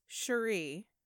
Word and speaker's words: Cherie: And I do believe it's Cherie. – Oh, okay. – Because that is how Joan pronounced it in the documentary Bad Reputation that I watched, and I assume she would know.